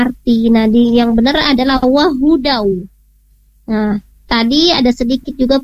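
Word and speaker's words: wahudaw (وَهُدًى): Nah, wahudaw tadi Bu Yuyun membacanya wahuwaw. Nah, di sini ada huda, wahudaw petunjuk ya.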